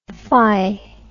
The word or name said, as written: phy